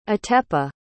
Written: atepa

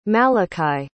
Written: malachi

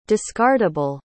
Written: discardable

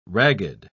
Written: ragged

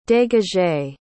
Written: dégagé

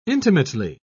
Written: intimately

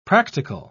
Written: practical

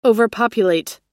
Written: overpopulate

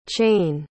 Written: chayne

